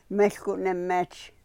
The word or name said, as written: mehl ku ne-mech